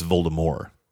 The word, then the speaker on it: Voldemort: Rowling said that the entire world pronounced it wrong because the movies pronounced it wrong, but it's Voldemort. Because in French, you wouldn't say the T.